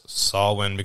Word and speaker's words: Samhain: So, Samhain, or Samhain, if you're from America, which is spelled Samhain, is pronounced Samhain because it's a Gaelic Celtic word.